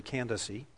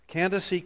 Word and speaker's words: Candace: And by the way, the pronunciation is actually Candace. Candace, queen of the Ethiopians.